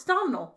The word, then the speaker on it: Domhnall: Jennifer Lawrence, Javier Bardem, Ed Harris, Michelle Pfeiffer, Brian Gleeson, I know how to say this. It rhymes with donut. – It's Domhnall.